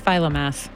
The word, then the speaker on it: Philomath: How do I say that? Philomath? Philomath.